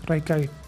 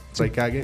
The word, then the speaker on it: Raikage: Sí, Raikage. – Raikage.